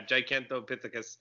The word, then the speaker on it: Gigantopithecus: Yeah, Gigantopithecus.